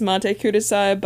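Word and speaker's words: Matte Kudasai: And then there was Monte Kudasai by King Crimson.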